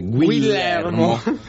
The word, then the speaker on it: Guillermo: O magari chi fosse italiano e non sa come si legge, lo spagnolo sarebbe Guillermo.